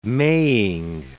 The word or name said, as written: maying